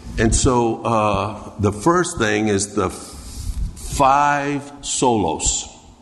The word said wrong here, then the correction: sola: Am I saying that right? Solos? Sola. Sola. The five solas.